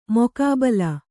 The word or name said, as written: ಮುಖಾಬಿಲೆ